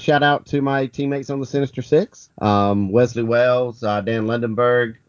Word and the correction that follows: Lundberg: It's really Lundberg. I'm notorious for mispronouncing his last name.